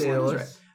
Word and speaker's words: Daedalus: Also, definitely said his name wrong. I don't know how to pronounce it. – No, I think it's Daedalus. Right.